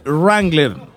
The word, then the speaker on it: Wrangler: La W no se pronuncia Wrangler.